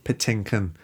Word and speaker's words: Patinkin: Patinkin.